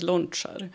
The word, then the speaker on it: Lončar: Och på C ska det vara ett V ovanför. Och det är det som gör att det uttalas lončar.